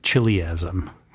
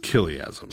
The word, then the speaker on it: chiliasm: Yeah, and if you've never heard this word said out loud before, the word looks like chiliasm, because it's usually spelled with a C-H. – Yes. Yeah. But it's pronounced chiliasm. – Chiliasm, because that CH represents the Greek letter he.